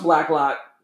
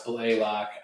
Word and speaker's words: Blacklock: was Ross Blacklock, defensive lineman, TCU. What say you? – Ross Blaylock. The more and more – I butchered the names.